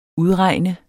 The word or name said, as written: UDREGN